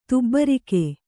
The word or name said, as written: ತುಬ್ಬರಿಕೆ